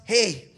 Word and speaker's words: He: Or, and then an H, which we would say, which they would pronounce, hey.